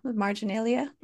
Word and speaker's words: marginalia: A couple poems later in the collection, you wrote about um, that the Hmong were made into mar- marginalia. How do you pronounce that correctly? – Yeah, marginalia.